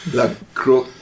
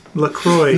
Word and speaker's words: La Croix: I think it's French, not Spanish. La Croix. – La Croix. – La Croix. Have you seen... – Thor says the same way as me, La Croixie.